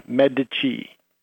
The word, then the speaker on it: Medici: It's like the Italian banking family who introduced double entry bookkeeping, M-E-D-I-C-I, Medici.